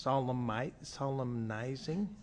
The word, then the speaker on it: solemnizing: Solemn keeps the N silent. Does that, when you lengthen the word, no. – Does the N, is the N pronounced solemnizing? – Is.